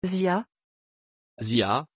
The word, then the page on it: Ziah: French